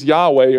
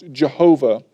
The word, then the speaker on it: Yahweh: Remember, we talked about this earlier, the word Lord there in Hebrew is Yahweh, or sometimes pronounced Jehovah.